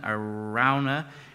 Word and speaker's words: Araunah: Rachel and I discussed how to pronounce that name, Araunah, Araunah. We settled on Araunah.